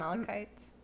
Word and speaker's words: malachite: I love malachites. – Okay, yeah, that is how you pronounce it.